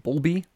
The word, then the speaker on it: Bulbie: And Hedy is also the first female recipient of the, what's called a bubble, I don't even know how to say it, Bulbie, B-U-L-B-I-E,